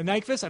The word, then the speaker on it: Nyqvist: Uh, uh, is it uh, uh, Nyqvist? – Uh, Nyqvist, I think that's how you pronounce it.